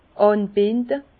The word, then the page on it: ànbinde: Bas-Rhin; Herrlisheim